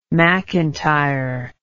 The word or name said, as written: mcintire